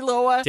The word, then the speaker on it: Loa: I know it's spelled L-O-A. – That's right. – How's that? – That's right. – It's not L-O-W-A. Okay.